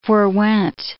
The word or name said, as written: forwent